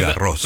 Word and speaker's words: Garros: Como ahora que se está disputando el torneo de Roland Garros, usted ya nos había explicado que no es Roland Garros. – No, es Garros. – Roland Garros, Garros, sí. – Claro, exacto. Es como la calle Jean Jaurès. – Exacto. – Y esa S final se pronuncia.